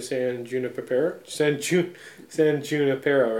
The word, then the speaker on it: San Junipero: And, yeah, also I will say it's a kind of a precursor to San Junipero, right? – I believe that's how you pronounce it, yes.